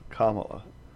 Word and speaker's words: Kamala: It's not Kamala. It's Kamala. – Kamala. – Yes. – Okay. – So it's not Kamala like Pamela.